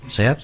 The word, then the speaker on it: SEAT: Uno de ellos trabajó en una fábrica de autopartes que le surtía a la SEAT, si lo dije bien, SEAT, sí.